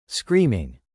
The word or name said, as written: screaming